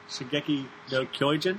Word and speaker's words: Shingeki no Kyojin: We've attempted to watch Attack on Titan, also known as Shingeki no Kyojin. I think I'm saying that right, Sherman? – Sure, close enough. – Okay. – Shingeki no Kyojin, close enough. I think that's what you said.